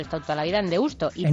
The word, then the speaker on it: Deusto: Sí, bueno, pero vamos, que a tope con los carnavales de Deusto, que por cierto, tenemos siempre discusiones con, ¿es Deusto? ¿Deusto? ¿Cómo se dice? – Yo Deusto he dicho toda la vida, ¿no? – ¿Cómo dices? – Deusto. – Deusto, vale.